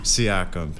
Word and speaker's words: Siakam: Siakam. – Pascal Siakam put up 34 points. He kind of carried the Raptors. James Harden put up 22 for the Sixers. – Yeah, dude, I don't think Siakam gets talked about enough.